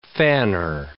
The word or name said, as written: fanner